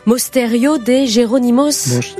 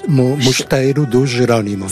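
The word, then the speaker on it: Mosteiro dos Jerónimos: Et quand on parle, donc, je l'ai mal prononcé tout à l'heure, mais Mosterio de Geronimos. – Mosterio de Geronimos. – C'est ça.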